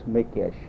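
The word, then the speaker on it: Mikeš: And then, uh, see, my, my mother's maiden name was Mikes. And it's pronounced in Czech, it's Mikesh, M-I-K-E, M-I-K-E-S.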